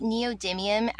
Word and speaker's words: neodymium: Yeah, so, you know, we just talked about neodymium as a strong magnet, and they're often made into novelty toys.